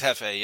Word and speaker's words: Hefe: Pretty much any event, major or minor, we're going to put there's a, I don't know if you know Hefe, or I think that's how you say his name, Jeffy. – Hefe Airsoft. Yeah, yeah. Yeah. It's Hefe, yeah.